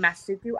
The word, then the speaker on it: Masuku: i feel like people uh, would butcher that name so bad so i just said Masuku. And people still uh, butcher it here and there, but it's not as bad as Masuka. So it's Masuku.